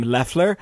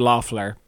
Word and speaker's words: Loeffler: But I've been like trained to pronounce this name Leffler, but I think. The boys pronounce it Loeffler.